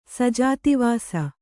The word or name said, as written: ಸಜಾತಿವಾಸ